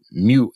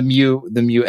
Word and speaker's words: Mu: And one of the great things, or one of the best things to pair with CircuitPython, is the Mu editor. I pronounce it Mu, sort of like the Greek letter. – Yeah. – Mu. I don't know if it's supposed to be Mu.